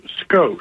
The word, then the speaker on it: Skosh: Well, my name is Skosh. – Skosh, very good. How do you spell that? – S-K-O-S-H.